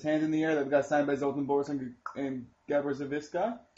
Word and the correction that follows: Zoltan Boros: Zoltan Boros and Gabor Zixi? – I just like you.